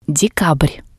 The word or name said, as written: dekabr